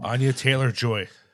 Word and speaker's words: Anya Taylor-Joy: Anya Taylor-Joy.